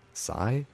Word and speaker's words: HSY: And also before that, I played a band called Psy, is how I pronounce it, H-S-Y, from Toronto, kind of a noise rock, I don't know, it's noisy shoegaze music from Toronto.